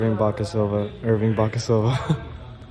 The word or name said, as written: Baca-Silva